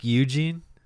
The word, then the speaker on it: Eugen: Not like Eugene? – I think it's like Eugene. It's spelled E-U-G-E-N for the listener out there. I think it's Eugen.